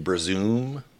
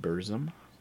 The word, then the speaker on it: Burzum: Have you heard like Burzum? – You mean Burzum? – Oh, sorry.